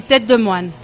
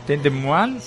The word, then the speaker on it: Tête de Moine: Tête de Moine. – Sí. – A ver, repítelo, ¿cómo es? – Sí, Tête de Moine. – Tête de Moine, sí.